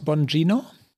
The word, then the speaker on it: Bongino: In dem Ausschnitt fragt, äh, wie spricht man ihn aus? Bongino? – Ja, so habe ich es gehört. So stellt er sich selbst vor.